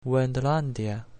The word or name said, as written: Wendlandia